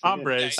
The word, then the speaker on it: hombres: Did he actually pronounce the H? – No, he said bad hombres. – Hombres. – Oh, okay. – Hombres. Yeah. I said hombres. – Yeah. – Would have been much funnier if he said hombres. – Silent H.